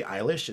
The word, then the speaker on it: Eilish: Billie Eilish.